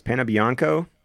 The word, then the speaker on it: Panebianco: You have got River Phoenix, Lili Taylor, Richard Oh, where did we come down on this? Panebianco? – That's perfect.